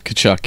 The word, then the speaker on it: Tkachuk: Matthew Tkachuk, am I saying that name right? – Tkachuk, yeah. – Silent T there.